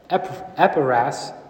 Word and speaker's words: Epaphras: And then in Philemon, Paul says, Epaphras.